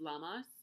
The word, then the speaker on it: Lughnasadh: We have my favorite to pronounce, Lunasa. Lunasa. I'm going to spell it for you again. Lughnasadh. Lunasa. Lunasa. Or it can also be pronounced Lamas or Lamash.